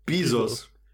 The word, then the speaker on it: Bezos: Ähm, Bezos. Oder was habe ich gerade gesagt? Bezos. – äh, englische Aussprache.